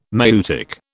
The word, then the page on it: maieutic: British English